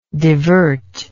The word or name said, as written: divertible